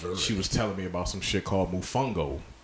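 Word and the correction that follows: mofongo: And she was like, it's pronounced Mofungo.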